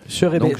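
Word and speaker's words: Scheurebe: Cherebé. Ça s'écrit S-C-H-E-U-R-E-B. Je le découvre en même temps que vous, donc voilà.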